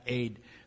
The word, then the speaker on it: Ed: It's spelled E-D, but it's pronounced Aid, which is the Hebrew word for witness.